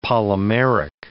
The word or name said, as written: polymeric